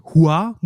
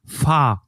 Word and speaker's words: Hua: Und zwar, dass dieses Fa Mulan eigentlich die kantonesische Aussprache ihres Namens wäre, was aber falsch ist, weil im Mandarin wäre es halt wirklich Hua, also H-U-A quasi in der Lautschrift,